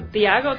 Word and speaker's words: Tiago: A ver, espérate, es TH, ¿no es cierto? Tiago, T-H-I-A-G-O. ¿Así se escribe? – T-I-A-G-O. – G-O. – Tiago. York. – I-O-R-C.